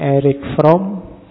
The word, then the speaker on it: Erich: Membacanya, kalau bahasa aslinya, karena lahir di Jerman, orang Jerman membacanya Erich Fromm. Tapi nanti dia saat sukses hidupnya di Amerika, orang Amerika membacanya lebih suka pakai Erich, jadi belakangnya K.